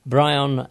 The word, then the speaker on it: Bryon: My name is Brian Edward Roberts. My Christian name, first name, is spelt B-R-Y-O-N, which is highly unusual.